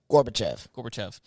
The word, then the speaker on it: Gorbachev: So back in the 80s when President Reagan was in office and Gorbachev, is that how you say his name? – Gorbachev.